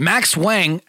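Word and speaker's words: Max Huang: Max Wang, actor, uh, I believe that's how you pronounce his name.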